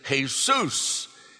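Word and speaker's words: Jesús: That means, in English, we say Jesus, but in Spanish, Jesus is Jesus. And you accentuate the second syllable. In English, Jesus, so we have to change the Spanish to Cristo, Christ. Cristo, C-R-I-S-T-O, Cristo.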